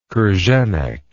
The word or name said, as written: krenek